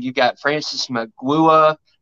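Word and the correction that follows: Mauigoa: I probably butchered that last name. – Francis Maui Goa. – Maui Goa, okay.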